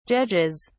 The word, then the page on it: judges: British English